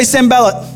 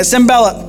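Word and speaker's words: Sanballat: Can you say Sembalat? Say it like you mean it, Sembalat.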